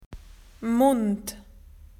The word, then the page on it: mouth: Australian English